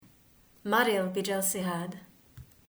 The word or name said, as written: Maril Bi Jelsihad